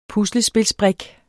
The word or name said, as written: puslespilsbrik